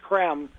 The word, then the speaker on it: Creme: And a friend of mine told me that this man named Benjamin Krem, it's spelled C-R-E-M-E, but looks like cream, but it's Krem.